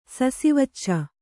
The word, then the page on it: ಸಸಿವಚ್ಚ: Kannada